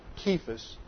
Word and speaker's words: Cephas: This is the Aramaic, or really, it should be pronounced Cephas, because none of these uh, Latin or Greek do, do not have a soft C like we pronounce in English.